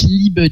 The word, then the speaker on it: liebe: Ich liebe dich. Le E se prononce, mais je n'en veux pas.